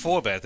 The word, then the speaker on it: Forbath: Well, at least Forbath, that's how you pronounce his name.